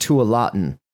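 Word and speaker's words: Tualatin: I think it's Mayan. It's pronounced Tulatin.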